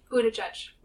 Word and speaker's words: Buttigieg: No, it's Buttigieg. – Buttigieg. Yeah, it is. – Yes. – So I've been saying it wrong.